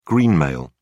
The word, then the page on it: greenmail: British English